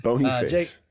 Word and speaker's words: Boniface: B-O-N-I. – How do you spell it? – B-O-N-I, face.